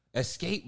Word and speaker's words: Xscape: No, no, Escape is spelled X. – Phase up. – X S C A P E.